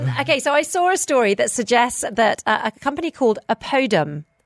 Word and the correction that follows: Oppidum: Opidum. – Sorry, there are too many syllables. – Sounds like a medicine. – It sounds like a medicine. And the next word is bunkers. oppidum bunkers.